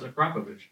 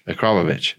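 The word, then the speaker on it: Akrapovič: Akrapovic. – That's a hard one. No, it's Akrapovich. – Akrapovich.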